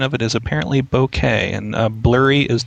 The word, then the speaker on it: bokeh: Pronunciation of it, he says, is bokeh, with the W in bow being nearly silent.